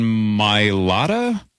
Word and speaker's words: Mailata: How do you say this guy's name? Philadelphia left tackle Jordan Mailata? – I think that's pretty close.